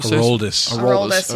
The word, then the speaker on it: Aroldis: And is Adronis, is that how you say it? – Aroldis, yeah.